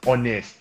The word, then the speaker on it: honest: Like, yeah, the word is honest. The H in honest is silent.